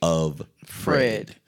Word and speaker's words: Offred: but her name is spelled O-F-F-R-E-D, Offred. And they kept saying Offred.